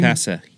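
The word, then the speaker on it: cachaça: Chicasa. Yeah, it's like a Brazilian rum, basically. – It's like, and it's such a quiet CH on the front, I always get confused on how to get it out, but...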